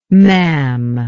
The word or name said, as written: maam